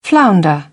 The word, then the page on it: flounder: British English; əɹ